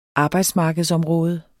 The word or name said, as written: arbejdsmarkedsområde